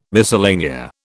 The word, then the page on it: miscellanea: British English